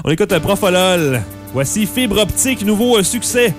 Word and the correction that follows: Propofol: Propofol, et non pas Profolol, je suis pas capable de le prononcer comme faux ce nom-là.